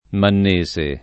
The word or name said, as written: mannese